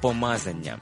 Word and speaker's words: помазання: А як же правильно казати помазання чи помазання? Правильно казати помазання.